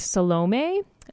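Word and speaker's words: Salomé: I believe that the name is pronounced Salome. It's S-A-L-O-M-E with a little guy on it.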